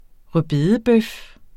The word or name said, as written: rødbedebøf